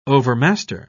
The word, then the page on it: overmaster: British English